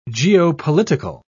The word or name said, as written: geopolitical